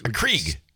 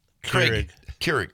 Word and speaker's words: Keurig: Johnny brought in a coffee maker because Krieg. – Keurig.